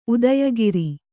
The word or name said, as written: ಉದಯಗಿರಿ